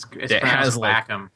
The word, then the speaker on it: Wacom: it's Wacom. – Like, Wacom? That's crazy. – Yes. – Maybe it's not. I don't know. – It is pronounced that way.